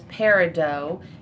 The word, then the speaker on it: peridot: August's birthstone is peridot. It's spelled P-E-R-I-D-O-T, but the T is silent.